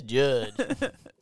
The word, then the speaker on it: Judd: Love how you get part of his name wrong every time. – Judge Reinhold. – Judge Reynolds. Judge Reynolds. Judge Reinhouse. – My name is Judd.